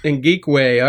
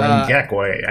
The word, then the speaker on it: Ngakoue: Ngakoue. – All right. Yeah, I butchered that, but I knew I would. – Or Ngakoue, I think. It's one of the two, but that's how it's pronounced.